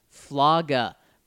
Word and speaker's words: flaga: Yes, he says, I meant flaga, pronounced like saga. You take saga and replace the SA with flow, but fla is short for Flacco and Locke. You know what?